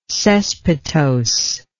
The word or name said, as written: cespitose